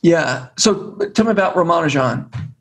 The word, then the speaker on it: Ramanujan: So some people say Ramanujan, that's the British pronunciation, but it's impossible for us to say it correctly because it's four unstressed syllables, the way that Tamil people say it. And I think it's just almost impossible for an English speaker to say four unstressed syllables in a row properly.